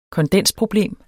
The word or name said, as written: kondensproblem